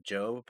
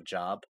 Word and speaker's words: Job: He tries to quote the Book of Job, but he calls it the Book of Job.